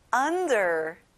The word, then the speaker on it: under: 아래에 under. 친구들, 언이 아니라 아 소리 내는 거예요.